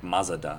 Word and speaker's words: Mazda: Like, there's an A in Mazda that we know, like, we don't pronounce, but they do. It's like Mazda. – Mazda? – Like, Mazda.